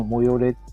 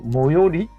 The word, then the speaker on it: 最寄り: この模様れ、模様、模様りって読むんだね。